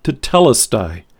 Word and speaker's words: tetelestai: It's pronounced tetelestai.